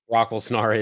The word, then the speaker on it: Brock Lesnar: Brocklesnar is the correct pronunciation.